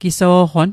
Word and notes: giso'qon: Mi'gmaq

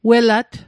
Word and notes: welat: Mi'gmaq

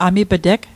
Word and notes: amip'teg: Mi'gmaq

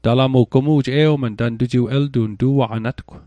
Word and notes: tu'aqanatgw: Mi'gmaq

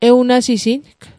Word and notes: ewnasising: Mi'gmaq

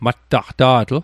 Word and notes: mattaqta'tl: Mi'gmaq

